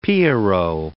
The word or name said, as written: Pierrot